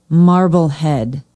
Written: marblehead

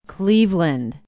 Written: cleveland

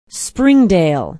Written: springdale

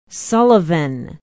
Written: sullivan

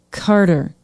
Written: carter

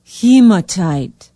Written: hematite

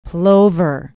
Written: plover

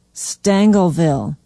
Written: stangelville

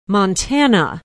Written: montana

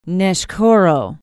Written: neshkoro

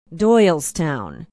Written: doylestown

